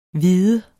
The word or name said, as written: hvide